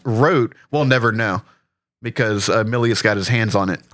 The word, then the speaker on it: Milius: Milius got his hands on the script.